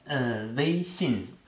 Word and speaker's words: Weixin: WeChat, habe ich das richtig ausgesprochen? – Weixin.